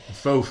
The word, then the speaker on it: faux: Fox fur is. – Fake. Faux fur is fake. – National Fritters Day and National Mutt Day. Why is there an X? – Because it's silent.